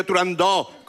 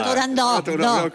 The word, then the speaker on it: Turandot: En el mundo exótico en el que vive Turandot, con abierta. – Turandot, con abierta.